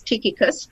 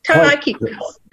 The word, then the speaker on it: Tychicus: and I always want to pronounce this one as Tychicus, but it's not. How do you pronounce that one? Tychicus. That's right.